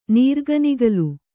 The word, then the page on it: ನೀರ್ಗನಿಗಲು: nīrganigalu